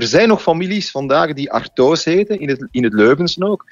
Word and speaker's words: Artois: Die wa achteraan, dat is natuurlijk de Franse uitspraak. En de naam Artois is in ieder geval een verbastering van een Frans woord.